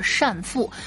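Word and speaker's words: 单父: 项羽善负着，就是那个单双的单，床单那个单啊，那是一个多音字嘛，还念善，在姓氏或者地名里叫善父。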